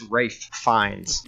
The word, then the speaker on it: Ralph Fiennes: Ralph Fiennes. That's how you say his name. I learned that today. And that, I was like, it's not Ralph. It's not Ralph. It's Ralph Fiennes.